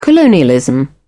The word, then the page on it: colonialism: British English